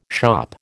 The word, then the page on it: shop: American English